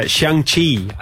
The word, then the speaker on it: Xiangqi: We've obviously translated for general, elephant, and guard, but Xiangqi, I think, is the way you pronounce Chinese chess.